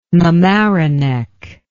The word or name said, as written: mamaroneck